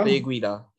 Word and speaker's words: Guida: Guida? – Clay Guida.